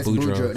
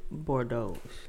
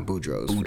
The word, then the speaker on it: Boudreaux: Doing it's not called Boudreaux. – It is called Boudreaux. – That's how it's spelled. Yeah, that's what it's called. – I thought it was Bordeaux. – Nah, Boudreaux.